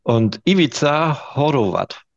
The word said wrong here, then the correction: Horvat: Ivica Horvat. Ja, du hast du noch den Ivica, den hast du, den hast du drauf.